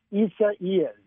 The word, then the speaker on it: Isaias: I went to the Weather Channel, and I listened to how their the people there were pronouncing it, and they pronounce it Isaias. Isaias. – Isaias. – Just like it's spelled.